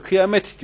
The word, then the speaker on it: kıyamet: Şimdi Arapçasını bilenler varsa, Arapçada ki o kıyameh denir duruşta, te diye okunmaz. Te vardır ama okunmaz. Biz Türkçe'de okuyoruz onu, kıyamet diyoruz. Yani biz bir araba kıyamet deseniz bu ne diyordur? Evet, orada ta olduğunu bilir de okunmaz. Kıyamet diye okunur.